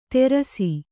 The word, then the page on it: ತೇರಸಿ: kannada